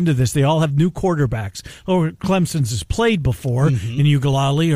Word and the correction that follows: Uiagalelei: But Uiagalelei.